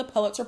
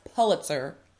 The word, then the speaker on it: Pulitzer: want you to know that all black people are not ignorant and we can all, some of us can pronounce Pulitzer Prize or Pulitzer. Some Pulitzer. – people say Pulitzer. It's, I don't know, tomato, tomato, whatever.